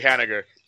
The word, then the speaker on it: Haniger: Did I say that right? – I'm not sure. I know nothing about it. So we're not going to be correcting my pronunciation. – It's actually Haniger.